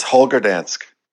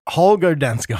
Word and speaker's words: Holger Danske: Holger Danske. – It's Holger Danske. – Holger Danske.